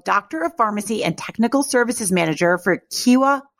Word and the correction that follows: Kyowa: It's Kyowa, new word, Hakko.